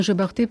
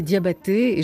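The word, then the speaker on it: Jobarteh: Sana Jebarté, alors je dis bien Jebarté parce que dans certaines biographies, on nous dit de prononcer Diabaté, et je vous ai posé la question avant de démarrer cette émission, on dit non, non, non, Jebarté, parce que ça c'est la prononciation gambienne.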